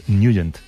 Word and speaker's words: Nugent: Pero la pronunciación correcta es Nugent.